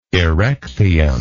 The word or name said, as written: erechtheum